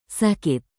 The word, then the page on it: sakit: indonesian